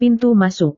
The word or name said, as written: pintu masuk